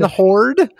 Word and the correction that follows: Harrison Ford: Did I say Harrison Horde? – Harrison Ford.